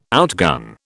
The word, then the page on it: outgun: British English